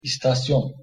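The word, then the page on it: istasyon: Turkish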